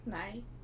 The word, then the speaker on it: nej: Nej.